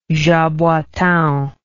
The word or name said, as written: jaboatao